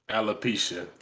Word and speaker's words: alopecia: Alopecia.